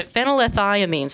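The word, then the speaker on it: phenylethylamine: Or it also has a phenylethamine. – Phenylethiamine. – Sorry, I missed one of the syllables.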